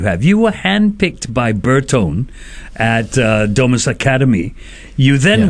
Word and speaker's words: Bertone: And Bertone, pronounced as Bertone, was one of the houses that was like 101 years old.